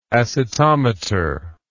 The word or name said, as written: acetometry